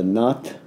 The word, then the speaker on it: Nath: Really, it goes back to the Kanpata yogins, the Nath lineage, that's N-A, long A-T-H lineage, that probably begins with Matsyendranath and then really gets launched with Goraknath.